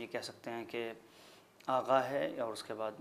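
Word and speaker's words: آقا: آغا کا لفظ استعمال ہوتا ہے. درست ہے کہ اس کا جو املا ہے وہ قاف کے ساتھ ہے مگر تلفظ جو ایرانی ہے وہ اس کا آغا ہو جاتا ہے.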